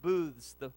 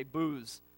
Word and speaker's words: booths: I didn't say booze. I said booths, okay? Booths. All right, did I say it right? Last time I said that, it would say, did you say booze? No, booths.